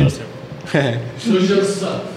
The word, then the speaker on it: Lose Yourself: Lose Yourself.